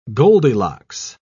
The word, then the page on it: goldilocks: British English